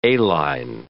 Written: A-line

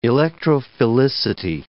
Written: electrophilicity

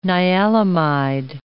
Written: nialamide

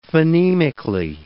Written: phonemically

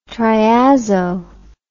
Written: triazo

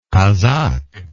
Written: casaque

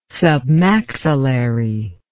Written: submaxillary

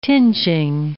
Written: tinging

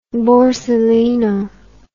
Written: borsalino

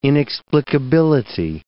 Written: inexplicability

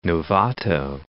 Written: Novato

